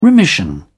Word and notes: remission: British English

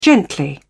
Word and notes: gently: British English

